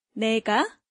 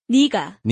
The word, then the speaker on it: 네가: 내가. – 내가, but in real life situations, people say 네가. – 네가. – 네가. – In actual spoken Korean, the word is pronounced as 네가.